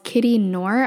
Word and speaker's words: Knorr: It's by this creator who's called Kitty Knorr, I believe it's pronounced. Kitty and then K N O R R.